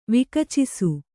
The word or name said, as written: ವಿಕಚಿಸು